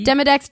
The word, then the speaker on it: Demodex: D-E-M-E? – Demodex, Demodex. I actually looked up pronunciation, and you can say it either way.